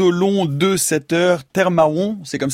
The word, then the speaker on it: Tè Mawon: Parce qu'on doit, il y a un accent sur le et. En fait, c'est Termaron. – Ouais. C'est un et comme le lait. – Ouais. – Et en fait, l'accent marque la chute du R du mot terre. – D'accord. – Donc voilà, on peut prononcer Termaron très très vite, Termaron. – Termaron. – Et comme ça, on n'entend pas les R.